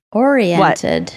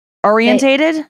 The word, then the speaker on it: oriented: People often give this word an extra syllable. Oriented. What? – Orientated? – Yeah, they say oriented.